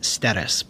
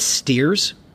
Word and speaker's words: Steres: glad his name is pronounced Steres, because if it's Steers, there's a saying about Texas and Steers that I can't say anymore.